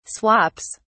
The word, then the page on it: swaps: British English